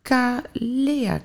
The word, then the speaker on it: Kalea: Kalea Kliniek. Klopt dat? Zeg ik het goed uit? Ja, heel goed.